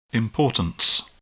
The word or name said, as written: importance